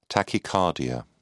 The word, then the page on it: tachycardia: British English